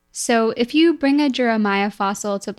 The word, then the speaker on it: Juramaia: We're going to actually be talking about one of the first of our kind, the first placental mammal, Juramaia, which is how I think it's pronounced. Um, Jeremiah, like Jeremiah was a bullfrog, yeah.